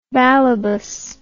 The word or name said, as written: balebos